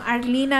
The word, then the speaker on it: Arlyn: Arlene Adriana. Arlene with a Y. – Like Arlen, Texas? – No, like A-R-L-Y-N and then Adriana. – Can you spell that for us, please? – A-D-R-I-A-N-A.